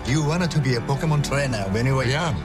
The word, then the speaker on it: Pokémon: Oh, you butcher it. Pokemon. – Oh, Pokemon.